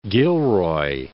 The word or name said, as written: Gilroy